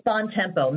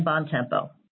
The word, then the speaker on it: Bontempo: It's Bontempo, Mary Fran Bontempo. – Can you spell it for me real quick? – Yes, it's B as in boy, O-N-T-E-M, P as in Paul, O, just like it sounds, Bontempo.